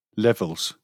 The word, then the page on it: levels: British English